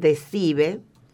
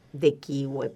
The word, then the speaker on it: De Cive: Si la quieren encontrar, está en el prólogo de un libro anterior de Job, que tiene un título en latín, De Cive, de kiwi, pero no lo vamos a pronunciar así, que parecería una fruta,